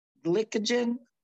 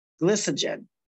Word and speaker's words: glycogen: G-L-Y-C-O-G-E-N. I'm going to go with glycogen. Kind of sounds glycogen. – Glycogen. There we go. See, it's a long I instead of a short I, soft I.